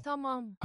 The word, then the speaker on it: Tamam: Tamam.